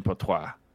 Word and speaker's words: Poitras: It's Poitras. Yeah, it's definitely Poitras.